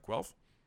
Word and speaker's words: Guelph: Did I say that right? Guelph? – Yep, perfect.